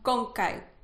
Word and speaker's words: ConKay: Con Kai. – Y se escribe... – C-O-N-K-A y griega.